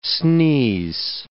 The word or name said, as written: sneeze